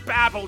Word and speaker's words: Babel: Hey, you're like a real-life Tower of Babel. Oh, I believe it's pronounced Babble Chad, which I admit you're doing an awful lot of.